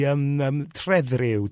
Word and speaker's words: Trefriw: It is the Trefriw, T-R-E-F. R-I-W.